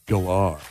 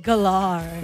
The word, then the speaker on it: Gellar: Sarah Michelle we all say Sarah Michelle Gellar. Apparently you pronounce it Gellar. – I knew that actually. Mainly because they did it like at the end of the Scooby-Doo movie from like 15 years ago. They do a pronunciation of all their names and they said Galar.